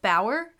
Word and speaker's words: Bauer: It's Wolf Bauer, I'm pretty sure is how you say it. It's B-A-U-E-R.